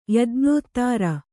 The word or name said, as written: ಯಜ್ಞೋತ್ತಾರ